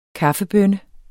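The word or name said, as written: kaffebønne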